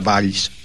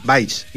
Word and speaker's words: Valls: Tienes que pronunciarlo con I, Valls, y entonces quedas bien.